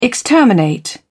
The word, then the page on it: exterminate: British English